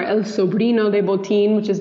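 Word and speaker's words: Botín: It's spelled B-O-T-I-N, Botin? – Botin.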